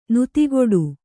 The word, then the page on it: ನುತಿಗೊಡು: nutigoḍu; kannada